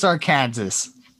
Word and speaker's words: Arkansas: It's Arkansas, yeah, obviously. – You can't just... Yeah, what's up with that? You know, I feel like having Kansas be pronounced Kansas and Arkansas being pronounced not Arkansas is